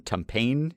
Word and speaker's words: Tumpane: But umpire John Tumpane, if that is how you pronounce it, T-U-M-P-A-N-E. – I think that's right.